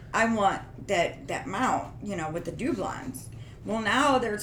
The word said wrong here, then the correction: doubloons: Doubloons. They're it's pronounced doubloons.